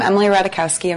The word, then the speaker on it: Emily Ratajkowski: I'm Emily Ratajkowski and wanted to have a quick chat about something important.